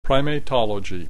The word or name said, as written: primatology